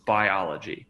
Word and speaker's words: Buyology: It's spelled B-U-Y-O-L-O-G-Y. So Biology. And that is the summary of all the findings that Martin found in that $5 million neuromarketing study.